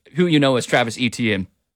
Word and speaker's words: Etienne: They brought in Travis, and I'm going to struggle with his name this year, now that we're pronouncing this differently, Etienne, or Travis Etienne, as you probably know him as.